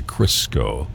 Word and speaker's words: Crisco: And that is an incredible album, by the way, Crisco. Okay, rhymes with Cisco, and they hang out at the disco.